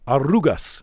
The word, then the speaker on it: Arrugas: The first one is called, and you can correct me if I'm pronouncing it wrong, Arrugas by Ignacio Ferreras. – Yes, exactly. Perfect. Gorgeous pronunciation. – Right. – The double R there, which means wrinkles.